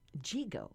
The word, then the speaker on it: Yigo: He's from Jigo, right? That's yes. – How you say it, spelled Y-I-G-O.